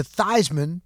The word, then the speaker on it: Theismann: When he went to Notre Dame, the wherewithal, the foresight, his name was Joe Theismann out of New Jersey, and he changed his name to Theismann to rhyme with Heisman, trying to get that Heisman trophy.